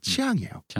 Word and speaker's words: Chiang: 이렇게 할 수 있는데 창이 아니라 우리나라에서 창으로 번역되지만 치앙이에요. – 창. – C-H-I-A-N-G.